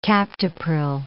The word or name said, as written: captopril